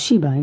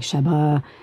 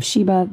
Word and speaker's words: Sheba: Like my first name is Sheba. It's usually pretty easy to pronounce. It's she and then ba, but there'll always be people who will say like Sheba, you know, instead of Sheba, that kind of thing.